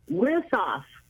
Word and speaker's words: Wisoff: Right, first of all, it's Wisoff. – Wisoff. – Right. – Okay, thank you.